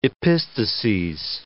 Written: epistases

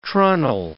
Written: trunnel

